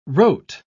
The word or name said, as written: rote